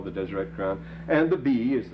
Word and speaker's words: Deseret: The word holy here is written very phonetically, Desireo, it's Deseret.